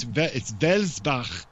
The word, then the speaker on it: Welsbach: Actually, it's Welsbach, if you want to say it properly in German.